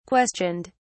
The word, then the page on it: questioned: British English